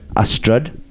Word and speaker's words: Ystrad: Spelt Y-S-T. – What? – Yeah. Y-S-T-R-A-D. That's the first word. Ystryd. And the second one is Mynach, which is M-Y-N-A-C-H, right?